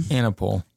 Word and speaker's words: Anaple: Anaple. – Anaple. Mm-hmm. I feel like people say it probably most people say Anaple, but I don't really.